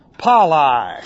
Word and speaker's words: palai: Palai. Looks like this. P-A-L-A-I.